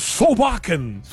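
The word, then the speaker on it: Solbakken: Durante a nossa visita ao estádio, a coletiva do Sobekin, é assim, né, que a gente vai ter que pronunciar, Sobekin, mas é assim que a gente vai falar, mas é Sobekin,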